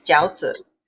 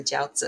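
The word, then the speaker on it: Jiaozi (饺子): Yes. Jiaozi. Yeah, that's how you say it in Mandarin, Jiaozi.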